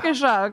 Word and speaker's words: Waukesha: Waukesha.